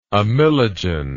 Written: amylogen

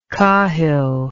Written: cahill

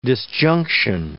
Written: disjunction

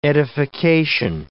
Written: edification

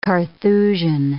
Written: Carthusian

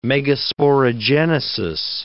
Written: megasporogenesis

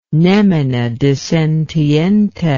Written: nemine dissentiente

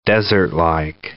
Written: desertlike

